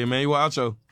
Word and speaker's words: Emmanuel Acho: Is it Emmanuel Acho? – How do you say? Uh-huh. Yeah, Emmanuel Acho.